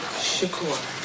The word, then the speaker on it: Shakur: Setua Shakur. – May I have you spell it? – S-E-K-Y-I-W-A S-H-A-K-U-R.